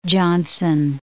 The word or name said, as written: Johnson City